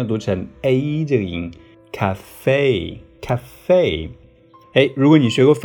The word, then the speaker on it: café: 成 cafe，生活中经常看到它。